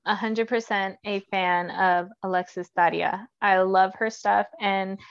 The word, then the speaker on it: Daria: Daria. – Fans. – Roll the R.